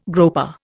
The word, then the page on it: groper: Indian